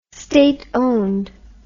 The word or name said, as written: state owned